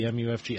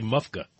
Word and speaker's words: MUFG: I get a note here, that says you can pronounce the MUFG. How do you pronounce that? – MUFG. – Okay. You don't say as a word. You don't say MUFGA. – No, we do not.